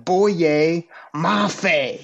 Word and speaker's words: Boye Mafe: Boye Mafe. You got to say it with some bass in your voice.